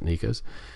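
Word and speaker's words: NECA: That's N-E-C-A. I guess you pronounce it Nika's.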